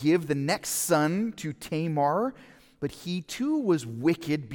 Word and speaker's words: Tamar: I was calling her Tamar. I was told that in English we pronounce it Tamar, okay, so I'm going to say Tamar if I can.